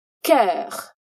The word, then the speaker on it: cœur: E no final, não podemos esquecer o som do R, que não pronunciaremos muito forte, tá? Não daremos ênfase nele, mas também não significa que ele não está por aqui. No todo, dizemos cœur.